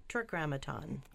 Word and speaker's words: tetragrammaton: Tetragrammaton. – Tetragrammaton? – Tetra. – Tetragrammaton. – Tetragrammaton. – Grammaton. Tetragrammaton.